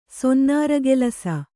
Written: ಸೊನ್ನಾರಗೆಲಸ

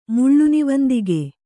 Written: ಮುಳ್ಳುನಿವಂದಿಗೆ

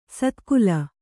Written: ಸದಾಕಾಲ

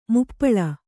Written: ಮಾಪಾಳ

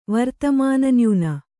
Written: ವರ್ತಮಾನನ್ಯೂನ

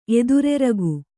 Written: ಎದುರೆರಗು

